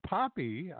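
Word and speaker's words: Poppe: Maddie Poppy, I want to keep calling her Pope. It's P-O-P-P-E, but she pronounces it Poppy.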